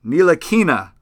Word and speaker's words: Ntilikina: I think they're going to go with the Euro kid, Frank Ntilikina is how you pronounce it.